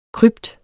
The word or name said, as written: krypt